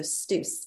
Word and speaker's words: STUCE: The Student Center is called the STUCE, by the way, S-T-U for student, C-E for center. So STUCE, it rhymes with loose.